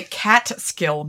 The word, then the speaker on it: Catskill: Down here, we say Catskill Mountains, and we spell it cat and skill.